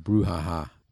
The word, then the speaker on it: brouhaha: Brouhaha. Is that how you pronounce That's how you pronounce it. Brouhaha.